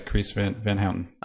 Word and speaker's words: Carice Van Houten: How do you say her name? – Yeah. You got it. Yeah, Carice Van Houten.